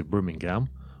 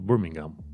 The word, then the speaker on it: Birmingham: Tu așa, un american așa zice Birmingham, Birmingham, dar uh, în UK se pronunță Birmingham, Birmingham, știi ceva de gen, uh, Nottingham, Tottenham, Durham, Wrexham, West Ham, ale e o excepție.